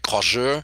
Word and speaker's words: croisure: And the the main trick that nobody told me that nobody knew about was a thing called croisure and it's actually a French word, and I'm sure it sounds something more like croiseur, but I'm not a French speaker, and so I spell it and say it croisure so that it's easier to remember.